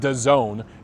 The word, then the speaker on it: DAZN: They have a streaming service, DAZN, it's pronounced DAZN, it's out of the UK.